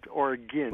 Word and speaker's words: Oregon: Yeah, it's pronounced Oregon, not like argon gas, but Oregon.